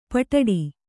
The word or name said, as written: ಪೊಡ್ಡಿ